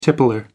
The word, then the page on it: tippler: British English